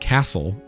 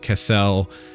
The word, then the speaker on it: Kessel: the natives of the system call it Kessel, but outsiders will often call it castle, which annoys the natives.